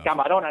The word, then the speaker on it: Camarón: Camarón. I think you forgot to roll the R.